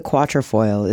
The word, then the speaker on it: quatrefoil: It's hard to know for sure why the quatrefoil is the global fancy shape.